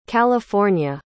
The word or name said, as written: california